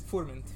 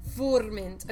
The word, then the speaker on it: Furmint: Furmint. – Furmint.